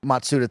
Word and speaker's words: Matsuda: At the start of the year, their president, Yosuke Matsuda, sorry if I mispronounced that, I got pretty close on that, said... – Matsuda, thank you, said gotcha, that the company's vision for 2023 included, quote, aggressive investments into blockchain technology and NFTs.